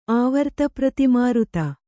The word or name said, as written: ಆವರ್ತಪ್ರತಿಮಾರುತ